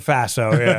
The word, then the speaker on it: FASO: Rhymes with lasso. I call it the FASO.